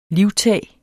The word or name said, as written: livtag